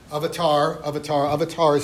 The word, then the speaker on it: avatar: So one of the ironic features of this word, avatar is just the North Indian pronunciation. In North Indian vernacular languages, when a word ends in short A, it drops.